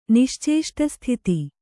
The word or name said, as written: ನಿಶ್ಚೇಷ್ಟಸ್ಥಿತಿ